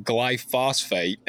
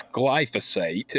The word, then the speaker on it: glyphosate: Most people commonly mispronounce it, call it glyphosate, and it is not. Yep. It's glyphosate. And I'm like, if you're going to try to attack me with it, at least say it right, you idiot.